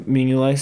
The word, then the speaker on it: Mignolet: because Bruges also has Simon Mignolet, I think is how you pronounce his. – Mignolet. – Thank you, Mignolet.